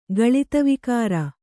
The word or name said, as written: ಗಳಿತವಿಕಾರ